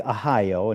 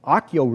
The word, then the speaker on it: Ahio: First, it looks like Ahio, and if you want to call it that, that's fine. But Achio really is how it's pronounced.